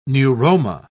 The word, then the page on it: neuroma: British English